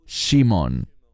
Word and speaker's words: Shimon: Estoy consciente de que en español se dice Simeón, pero literalmente, en el idioma original, la pronunciación correcta, debido a que todos eran judíos, sería Shimon. Y esa palabra, Shimon, ese nombre, tiene que ver con oír y responder.